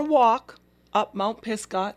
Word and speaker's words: Pisgah: Pisgah? – You did, absolutely.